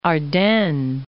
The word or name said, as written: Ardennes